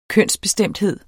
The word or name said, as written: kønsbestemthed